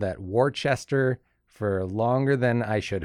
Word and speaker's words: Worcester: I should say that is that's how it's pronounced, Worcester.